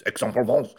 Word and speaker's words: Aix-en-Provence: We'll go to Aix-en-Provence, which I didn't know how to pronounce AIX, but it's Aix-en-Provence.